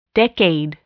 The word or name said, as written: decade